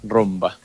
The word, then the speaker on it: Roomba: Rumba se escribe R-O-O-M-B-A. Rumba.